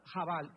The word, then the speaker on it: chabal: Cuando alguien se acerca a ti para darte un consejo en privado sin que los demás oigan, es sod, S-O-D de Daniel. En tercer lugar tenemos chaval. No sé si la C-H, creo que se pronuncia jabal, ¿verdad? Jabal es la pronunciación, se escribe C-H, A-B-A-L, jabal.